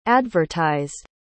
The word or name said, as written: advertised